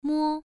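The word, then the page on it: mo: tone 1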